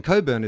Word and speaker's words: Cockburn: So I got a map, and Coburn is spelt C-O-C-K-B-U-R-N. So I was walking around to people saying, can you tell me where Cockburn Street is? – Right, because that's actually how it's pronounced.